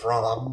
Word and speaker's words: Bram: It I always thought it was away. Pronounced Bram, but... – Probably is. It's like Brahms lullabies.